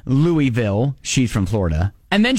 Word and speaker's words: Louisville: The town in Northeast Ohio that's right outside of Canton in the Football Hall of Fame is spelled the same way as Louisville, but it is pronounced Louisville.